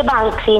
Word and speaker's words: Banksy: Si dice Banksy, non si preoccupi.